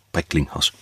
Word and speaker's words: Brecklinghaus: Mensch, Tobias von Brecklinghaus. Brecklinghaus. Ja, jetzt habe ich es richtig ausgesprochen. Der ist auch mega gut.